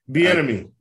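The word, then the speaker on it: Bieniemy: I mean, God, I mean, you talk about, I'm thinking, you know, where's Eric, you know, what, how do you say his last name? Benami or whatever. He interviewed, you know, Chiefs office. – Bienemy.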